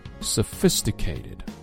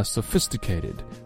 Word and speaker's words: sophisticated: 好了，来看一下今天的重点发音解析，我们来学一个长单词的重音，那大家应该猜到了，就是 sophisticated。你看这个词啊 sophisticated，哒哒哒哒哒哒，它的重音是这样哒哒，第二音节重读哒哒哒哒哒哒 sophisticated。